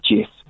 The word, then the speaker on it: Cif: Well, you know that in Turkish, if a word is written C-I-F, it's actually pronounced Jif. The C is pronounced as a J.